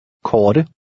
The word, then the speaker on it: Korte: The T sounds like a cross between the English T and D. – Korte.